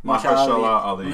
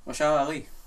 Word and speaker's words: Mahershala Ali: Marsha Ali. Am I pronouncing it right or not? – Mahershala Ali. – Yes, okay. – No, ma'am. You guys don't watch Nick Cage? Oh, my God. Mahershala Ali.